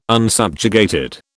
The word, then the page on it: unsubjugated: British English